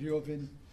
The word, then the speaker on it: Levin: I pronounce it Lyovin, which is the way the Russians pronounce it. But people who are descended from Russian immigrants to this country spell it L-E-V-I-N, and we pronounce it as it seems to be spelled in English. And you can call him Levin if you want to.